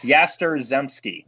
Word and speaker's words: Yastrzemski: Y-A-S-T-R. Zemski. – Yeah. – There you go. It's Yaster Zemski. That's how I remember it.